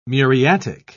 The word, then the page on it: muriatic: British English